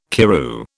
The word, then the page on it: Karroo: British English